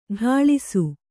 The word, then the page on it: ಠಾಳಿಸು: ṭhāḷisu